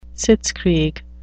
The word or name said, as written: sitzkrieg